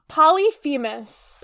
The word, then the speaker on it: Polyphemus: Polyphemus? – Polyphemus. – Polyphemus, sorry. Yeah. Well, Polyphemus, just a different pronunciation of the same spelling.